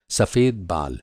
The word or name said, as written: बाल